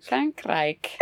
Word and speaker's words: Frankrijk: How do you say it in Dutch? – Frankrijk.